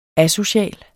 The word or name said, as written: asocial